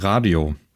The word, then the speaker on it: Radio: und zwar wird aus Information zukünftig Radio. Ich gehe davon aus, dass wir das Radio aussprechen und nicht Radio, weil wir ja immer noch auf Deutsch funken.